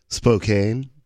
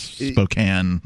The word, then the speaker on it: Spokane: And we have been talking about this article from several sources, but this one is from the Spokesman Review out of Spokane, or Spokane, Washington, if you want to mispronounce it. – Spokane. – Yeah, that's the mispronunciation. Yeah, it's Spokane when you're there.